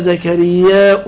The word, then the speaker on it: زكرياء: كان زكريا الحديث كان زكرياء بالهمزة في صحيح مسلم